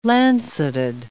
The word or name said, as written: lanceted